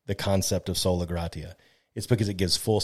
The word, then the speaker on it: sola gratia: But the 5th point is always the sola gratia.